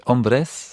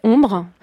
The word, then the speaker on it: Hombres: En sera-t-il de même avec un recueil qui là pour le coup est nettement plus pornographique, qui est le recueil, alors je ne sais pas s'il faut prononcer Hombres à l'espagnol ou Ombre, parce que ça s'écrit H-O-M-B-R-E-S. Pour vous, il faut le prononcer Ombre. – Oui, je dirais Ombre, mais les deux sont acceptables, je crois, parce que d'une part, Ombre signifie homme en espagnol, et donc c'est un recueil consacré sur l'homosexualité féminine, pardon.